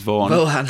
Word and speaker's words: Vaughan: James Vaughan. – James Vaughan. – Vaughan. That's the way he says Vaughan. – That's how we would pronounce it over in this part of the world.